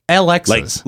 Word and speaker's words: Elexis: Because it's spelled E-L-E-X-I-S. Alexis. – Like elixir. – Yeah, exactly. – Mm-hmm. Like a magic potion, like an elixir.